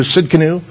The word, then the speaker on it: Tsidkenu: The T is silent, so it's Jehovah Tsidkenu.